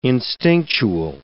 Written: instinctual

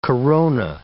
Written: corona

